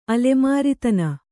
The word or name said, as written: ಅಲೆಮಾರಿತನ